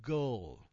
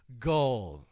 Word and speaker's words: Gaul: Gaul. Well, that's tough for me. Give it to me again. Goal. It's spelled as the football goal. Pronounced. I spell it G-A-U-L, but pronounced as football goal.